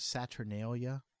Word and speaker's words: Saturnalia: Now, see, but the Romans, they celebrated this festival of Saturnalia.